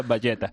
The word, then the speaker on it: Vegeta: Y por último, Nuño dice que su villano favorito es Vegeta, por el hecho de que en catalán se pronuncia Valleta.